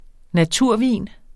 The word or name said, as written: naturvin